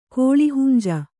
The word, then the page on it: ಕೋಳಿಹುಂಜ: kannada